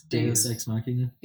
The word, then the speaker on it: Deus ex machina: How do you pronounce that word again? Deus. – Deus. – Deus. Deus ex machina.